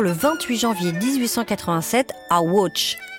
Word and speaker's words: Łódź: C'est ainsi que se prononce le nom de cette ville en polonais, mais j'espère que vous me pardonnerez de le prononcer à la française, comme le faisait d'ailleurs Rubinstein lui-même, c'est-à-dire Łódź.